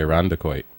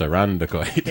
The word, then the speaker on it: Irondequoit: It's actually Irondequoit. So if you can't pronounce Charlotte, how are you going to pronounce Arundicoid? – Yeah, right, exactly.